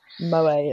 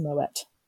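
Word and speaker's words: Moët: It's not pronounced Moe. It's pronounced Moet.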